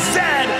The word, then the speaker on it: zed: The toque is a hat, the Chesterfield is a coach, and it is pronounced Zed, not Z-Z.